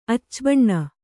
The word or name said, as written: ಅಚ್ಚಬಣ್ಣ